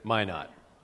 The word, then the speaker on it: Minot: I was in Minot, because it's a French word. – Minot.